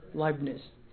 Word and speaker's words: Leibniz: Leibniz.